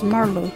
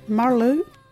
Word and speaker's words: marlu: Our last vocabulary word is marlu. What is a marlu? Marlu is a red kangaroo.